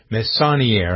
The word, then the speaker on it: Messonnier: The person who's the director for the Center of Disease Control, The Immunization and Respiratory Disease Center is Dr. Nancy Messonnier, I believe is how her name is pronounced.